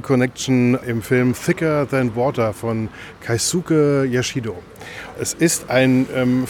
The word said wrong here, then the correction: Yoshida: Film über, jetzt habe ich wieder falsch gesagt, das ist Keisuke. – Yoshida. – Ach, ein Film von Keisuke Yoshida.